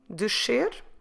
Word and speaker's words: descer: D-E-S-C-E-R. Descer. So with this verb descer, we have here a bit of a difficult sequences of sounds. So the first syllable of the verb ends with the sound sh, dsh, and then the second syllable starts with the sound s, ser. So if I'm pronouncing it correctly, I say descer. So a sh immediately followed by a s, descer, which is sometimes hard even for the Portuguese to say.